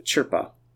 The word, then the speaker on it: Chirpa: He's saying, well, keep wanting to say his name wrong. Chief Chirpa. – I mean, it's not hard, because it almost starts with ch.